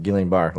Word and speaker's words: Guillain-Barré: I think it's Julian, isn't it? – Julian? Yeah. Guillain-Barré? – No, Julian, because it's... I think. You were saying it right the first time. I've heard it pronounced Guillain-Barré, like you were saying.